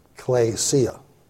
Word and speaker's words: ekklesia: Klesia, that K-L-E there, that's it. The first E is an epsilon, the second E is an eta. You're hearing the words, usually in alphabets, they name the word after the way you pronounce it. So, you know, the second E as an eta is pronounced as an A. So it's klesia, you know, which means to call.